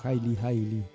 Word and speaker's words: Eli Eli: La siguiente canción que elegimos es el track número 6 de este disco, que se llama Eli Eli, se escribe, pero está pronunciado como Haile Haile, que tiene que ver justamente con Haile Selassie.